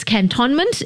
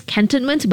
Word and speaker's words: Cantonment: So apparently, cantonment... It's cantonment, it's cantonment, but it's also, did you know, the British pronunciation that Susan just told me, cantonment.